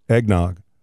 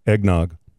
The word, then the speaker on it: eggnog: Nog, like dog, log, bog, nog. And they insist that's what it is. And will not. – Well, I kind of feel like that's what I'm saying. Eggnog. – No, you're not. – Rhymes with dog. Egg dog. Eggnog. Same thing.